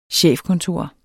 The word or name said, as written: chefkontor